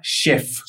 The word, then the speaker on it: Weff: Y el artista se llama Chef. Chef. Pero se escribe W-E-F-F.